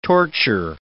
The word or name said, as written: torture